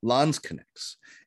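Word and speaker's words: Landsknecht: And he starts hiring mercenaries, lansknechts, which literally means foot knights or land knights, that word connect, you can see to the medieval pronunciation of the English knight, knicked, right?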